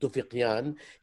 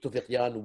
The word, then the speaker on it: Tufiqyan: And our guest author and writer is Omid Tufiqyan. He told me that we pronounce it like we do in Arabic with a qaf, and I was really impressed. So Omid Tufiqyan, welcome to our roundtable.